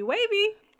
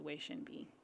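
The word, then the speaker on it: Wei Shen Bi (威神V): When you look at how it's spelled and phonetically, it just looks like Wavy. But I think they pronounce it Wei Shen Bi.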